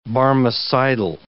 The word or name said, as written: Barmecidal